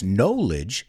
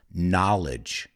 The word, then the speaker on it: knowledge: You could also say the word knowledge as well, but be very careful because the word know es saber, but the word knowledge, fijaos, no es knowledge, it's knowledge.